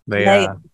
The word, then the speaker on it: Leah: No, it's Leah, right? – Leah. – Okay. And it's funny because I was watching an interview with you, and I think I was like, how do you pronounce her name? And then I think the person said Leah, so I'm like, it must be Leah.